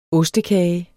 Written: ostekage

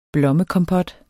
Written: blommekompot

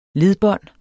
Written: ledbånd

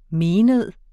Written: MENEDEN

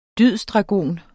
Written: dydsdragon

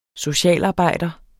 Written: socialarbejder

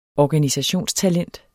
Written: ORGANISATIONSTALENT

